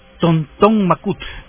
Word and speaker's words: Tonton Macoute: Se llamaban Tonton Macout, ¿verdad? Se escribe Tonton Macoute.